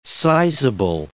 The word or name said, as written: sizable